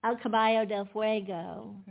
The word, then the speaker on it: El Caballo del Fuego: El Caballo del Fuego. – And you said that very well.